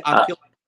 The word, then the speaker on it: Aquila: Do you say Aquila? – I was told by Mimo it was Aquila, and it means eagle in Italian.